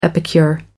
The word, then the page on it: epicure: American English